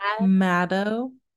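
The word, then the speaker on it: Madho: In 1573, insert damn, Bangar Fort was built by Raja Bhagwant Singh for his youngest son, Mado. – Maddo. – That's what I got as the pronunciation, but it is most definitely spelt like Mad Ho.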